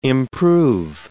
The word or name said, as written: improve